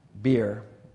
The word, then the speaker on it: bier: That is how you pronounce that, bier.